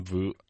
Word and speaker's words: Veuve: I love some Veuve.